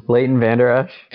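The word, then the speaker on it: Leighton Vander Esch: Is that how you say his name? – Leighton Vander Oosh.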